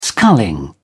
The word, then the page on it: sculling: British English